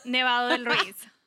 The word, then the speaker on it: Nevado del Ruiz: It's called Nevado del Ruiz. – Okay. What? How do you say? – It's called Nevado. – Nevado. – Del Ruiz.